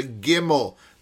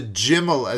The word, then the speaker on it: gimel: But he brought his mojo, his tradition from Yemen, 2,500-year-old tradition about how to pronounce the gimel, the jimel, as they would say.